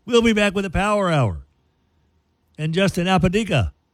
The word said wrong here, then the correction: Apodaca: Oh, no. I've already mispronounced it. Apodaca. Dang it.